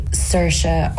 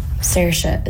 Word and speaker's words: Saoirse: It's Saoirse, but it's easier for Americans and English people to say Saoirse or Sasha. – What was the difference? – Saoirse is how Irish people say it.